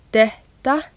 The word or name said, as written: detta